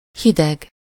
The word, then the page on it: hideg: /ˈhidɛɡ/